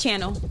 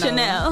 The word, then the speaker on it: Chanel: And my name is spelled with an S, not a C. Okay, it's not channel. It is Chanel.